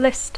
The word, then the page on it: list: English